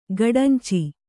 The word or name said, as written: ಗಡಂಚಿ